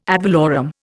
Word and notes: ad valorem: Indian

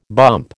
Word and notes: bump: American English

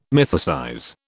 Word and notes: mythicize: British English

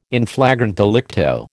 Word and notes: in flagrante delicto: American English

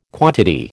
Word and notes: quantity: American English

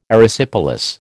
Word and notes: Erysipelas: American English

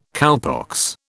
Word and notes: cowpox: British English

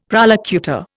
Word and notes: prolocutor: Indian

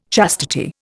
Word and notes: chastity: Indian

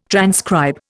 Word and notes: transcribe: Indian

